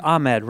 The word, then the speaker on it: Ahmed: You're like, Ahmed rhymes with bed.